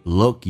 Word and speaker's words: look: Look 首先可不发音，同时它是一个短元音，这个发音特别难发，因为很多人发成 look，因为嘴巴说的太圆。其实在 oo 长元音的基础上，要打开空间更大。